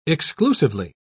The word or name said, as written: exclusively